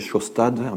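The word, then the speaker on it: Sjaastad: Alors, comment prononce-t-on ce nom? Les Américains disent Schostat, les Anglais disent Schostat, les Norvégiens disent Schostat. Donc, moi, je vais prononcer Schostat, un peu comme Shostakovich, ça va simplifier les choses.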